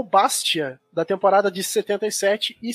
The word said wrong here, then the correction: Bastia: Não, não quero ter que corrigir o seu francês, mas é Bastia é como se tivesse um acento então, no fim.